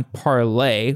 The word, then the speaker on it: ParlAI: You work on Parlay, which is spelled P-A-R-L-A-I.